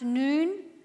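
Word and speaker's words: Noon (نون): The one on the left is in a house in Mosul owned by Christians, and Islamic State has marked it with an Arabic letter N, pronounced Noon, for Nasrani, meaning a follower of Jesus the Nazarene.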